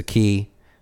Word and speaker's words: Cay: It's a key. It's spelled C-A-Y.